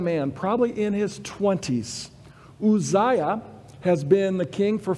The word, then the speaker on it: Uzziah: It says this, it says, in the year that King Uzziah, is how you really should pronounce it, and that Yah at the end, it comes from Yahweh, the one who gains his strength from Yahweh.